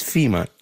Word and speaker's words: Fima: My name is Fima, just like the organization, but spelled with an I.